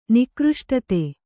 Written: ನಿಕೃಷ್ಟತೆ

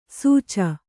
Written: ಸೂಚ

